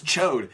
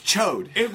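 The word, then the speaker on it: Ch'od: He's one of the Star Jammers, and his name's Chode. In my head, when I was a kid, I used to pronounce it Chod. – Yeah, is it or Chod? – No, it's Chode.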